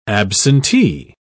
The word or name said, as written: absentee